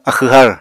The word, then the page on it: axw har: apatani